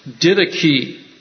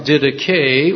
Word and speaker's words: didache: So there's the second word is didache. Or didache, D-I-D-A-C-H-E. It looks like did ache, but it's pronounced didache, or didache. And that particular word is in Greek the act of teaching.